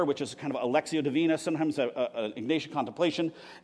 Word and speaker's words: Lectio: It's pronounced Lexio, but it's spelled L-E-C-T-I-O 365, done by 24/7 Prayer International.